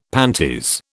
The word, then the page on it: panties: British English